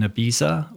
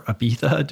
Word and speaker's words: Ibiza: I Took a Pill in Ibiza, or Ibiza, depending upon how you pronounce it, from his 2016 sophomore LP.